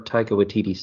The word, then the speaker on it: Taika Waititi: So I've seen more Taika Waititi stuff since Thor Ragnarok. – Good job pronouncing the name. – Yeah, well, Taika Waititi, Mjolnir.